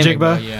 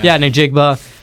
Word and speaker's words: Njigba: How do you say his last name? – Njigba. – Njigba. Yeah.